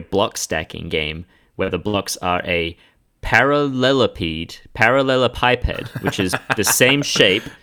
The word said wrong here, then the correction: parallelepiped: I feel like you said that wrong still. One more time. – It's a parallelepiped. – Parallelepiped. – Okay. That is correct, motherfucker, because I copied the phonetic Google and it's in front of me.